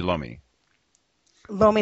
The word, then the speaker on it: lomi lomi: Has spent time studying in Hawaii on the Big Island in Hilo to learn directly from Lami Lami. Is that pronounced correctly? Or Lomi Lomi? – Lomi Lomi.